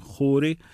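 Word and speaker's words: Khoury: Και πάμε να ακούσουμε τώρα έναν εξαιρετικό τρομπονίστα, Γαλλο-Λιβανέζο, το Ρόμπινσον Χούρι, KH γράφεται, έτσι προφέρεται στα αραβικά,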